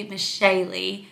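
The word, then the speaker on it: Meshali: The appropriate pronunciation is actually Michelle, not to be confused with Miss Shaley.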